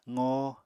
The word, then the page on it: qoo: apatani